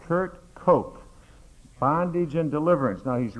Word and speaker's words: Koch: Kurt Koch, it's pronounced like Coke, but it's German K-O-C-H.